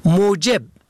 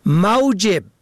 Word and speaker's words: موجب: موجب میم واو جیم بی به ضم اول سبب. موجب به فتح اول معاش.